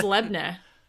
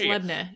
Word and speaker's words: labneh: I think it's Lebne. – Ah, oh, Lebne. – Okay. I think it's Lebne. Yeah.